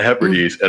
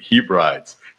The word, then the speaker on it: Hebrides: It was like, listen, I am in the Hebrides, and only I didn't know how to pronounce it. So I pronounced it Hebrides. But I thought, what a cool word, Hebrides. So the very first thing that I got, the idea for the setting of this story was wrong. The very first thing was a mispronunciation.